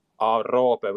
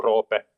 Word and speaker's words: Roope: So I'm a Finn, so in Finland we say Roope with an O. So you can think of how you pronounce then Robert, and you just leave out the earth at the end. So it's the same O sound. So Roope.